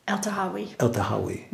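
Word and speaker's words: El-Tahawy: And one of the reviews referred to as an anti-patriarchal manifesto, written by Mona El-Tahawi.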